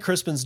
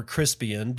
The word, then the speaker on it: Crispin: If you don't know, that's from the St. Crispin's Day or Crispian, depending on how you want to pronounce it. It's actually St. Crispin. I know, it's weird.